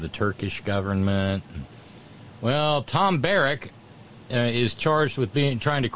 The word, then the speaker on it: Barrack: I've read several of these stories, and every time I see his last name, I want to pronounce it Barack. No, this is Tom Barrack.